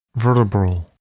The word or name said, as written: vertebral